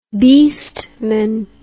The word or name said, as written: beastman